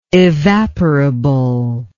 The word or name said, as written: evaporability